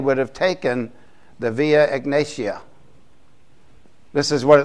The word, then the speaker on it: Egnatia: There's a Egnatia is how you pronounce it, the via.